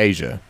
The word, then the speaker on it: Asia: As far as in ASEA, I'm sorry, Asia.